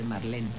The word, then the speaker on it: Marlene: que todo el mundo dice Marlene, pero es Marlene.